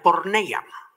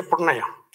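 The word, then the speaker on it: porneia (πορνεία): Entonces es la palabra G4202, que es la palabra en original se pronuncia porneia. – It's the, in the definition, it's the word G4202, and in the original it's called porneia. – Se pronuncia porneia.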